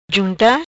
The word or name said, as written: Junta